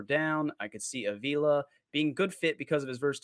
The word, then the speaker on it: Avila: Avila is how I think it's pronounced.